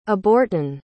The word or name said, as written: abortin